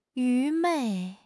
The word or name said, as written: 愚昧